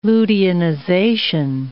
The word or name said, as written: luteinization